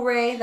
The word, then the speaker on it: Rayy: All right, well, once again, my name is Nicole Rayy.